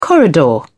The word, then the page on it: corridor: British English